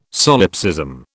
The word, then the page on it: solipsism: British English